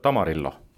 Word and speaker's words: tamarillo: see on selline tomati värvusega, natuke sihukest suuremat ploomi meenutav tamarillo mm. -hmm. ma nüüd hääldasin nagu see Amarillo aga, aga, aga, aga mis ta on?